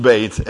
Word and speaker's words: Beit: Now, I mean, it's spelled Beth, but it's pronounced Beit.